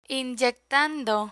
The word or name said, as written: inyectando